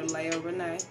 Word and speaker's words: Layah Renee: I'm under Layah Renee. It's spelled L A Y A H R E N E E. You gotta say the whole thing like a pimp named Slickback.